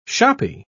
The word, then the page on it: shoppy: British English